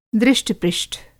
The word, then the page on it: दृष्टपृष्ठ: Hindi